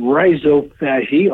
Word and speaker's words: rhizophagy: And so this group studied this, and they termed it, I'm going to give it the American pronunciation, rhizophagy, R-H-I-Z-O. Rhizo, of course, meaning root, and phagy, meaning eating, so root eating.